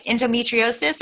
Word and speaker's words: endometriosis: And we'll talk about this in the next show, that you had the NDO, I can't even say it right. Endometriosis. – That was third time's a charm. That was perfect.